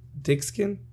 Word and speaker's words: Dickson: We have I would call him an amazing comedian, but Chris Dickson.